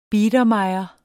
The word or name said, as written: biedermeier